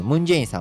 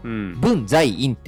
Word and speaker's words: 文在寅: 韓国の大統領のムン・ジェインさんを僕らはムン・ジェインって読むけど、文在寅って読んでもいいわけじゃない日本語読みすると、むあの文在寅。